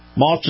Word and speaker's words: Maus: And remember, Moss is spelled M-A-U-S, Acura, of course, A-C-U-R-A.